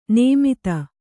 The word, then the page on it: ನೇಮಿತ: nēmita